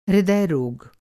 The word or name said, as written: हृदयरोग